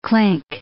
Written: clink

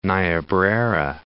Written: Niobrara

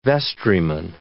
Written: vestryman

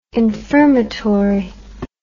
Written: infirmatory

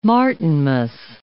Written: Martinmas

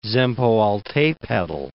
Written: Zempoaltepetl

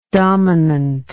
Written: dominantly

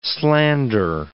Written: slander

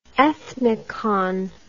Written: ethnicon

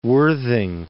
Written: Worthing

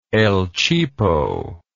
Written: el cheapo

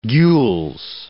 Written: gules

